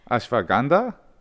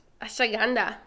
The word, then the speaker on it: Ashwagandha: Is that the pronunciation? – No, it's just the way I say it. – What is the correct pronunciation of that? Ashwagandha? – Yeah, right there. Ashawanda. – Ashwagandha? – Ashwagandha. – Something like that. – Something like that, yes.